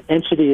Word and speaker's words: Enschede: R-Y-A-N is, of course, Ryan. Enchity is E-N-S-C-H-E-D-E.